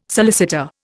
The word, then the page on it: solicitor: Indian